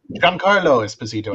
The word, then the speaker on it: Giancarlo Esposito: is the spotlight on Giancarlo Esposito. Did I say that right? – Giancarlo Esposito.